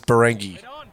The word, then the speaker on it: Barangi: So you've confirmed that it is Barangi. – Yes, that's how they pronounce it on their website. Okay. They've got like hyphens and dots and everything to really emphasize the to make sure you're not putting the emphasis on the wrong syllable.